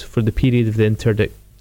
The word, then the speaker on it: interdict: One thing he did was declare an, is it pronounced, interdict? An interdict against Scotland. – Interdict, yeah.